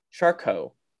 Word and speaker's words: Charcot: For the next time you're at Trivia Night and the category is famous French physicians or names that end in a silent T, we give you random facts about Jean-Martin Charcot.